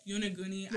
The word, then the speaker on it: Yonaguni: Yo Gunny by Bad Bunny. – Good. – Did I say that right? – Yonaguni.